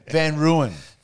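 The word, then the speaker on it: Van Rooyen: Well, with my very intimate knowledge of AFL football, I'm going to go for a chap called Jacob Van Rooy. – I told you 9 times the cunt's name and you can't even say it right. Van Ruin.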